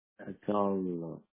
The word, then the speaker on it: Adarlo: Last name is A-D-A-R-L-O. – Adarlo.